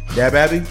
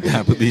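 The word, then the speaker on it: DaBaby: Say this shit right. – Dab-a-bee. The Babby.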